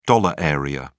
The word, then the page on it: dollar area: British English